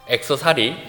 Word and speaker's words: 액세서리: 악세사리, 미국 온지 1년이 지났는데 발음이 이것밖에 안 됩니다. 악세사리 뭔지 아시죠? 우리 발음 제일 좋으신 우리 자매님 본토 발음으로 한번. 액세서리, 뭐 여튼 의미만 통하면 되니까, 액세서리와 보물의 차이는 무엇인가?